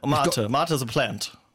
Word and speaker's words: Mate: And Club Mater, is that how you say it, or Mate? – Mater. – Mater.